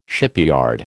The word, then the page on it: shipyard: American English